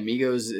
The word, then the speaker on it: Migos: Yeah, Migos is spelled like Legos, but with an M.